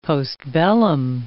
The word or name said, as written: postbellum